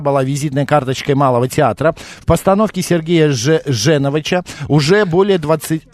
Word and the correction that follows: Женовача: Женовача, да, извините, ради бога, неправильно поставил ударение.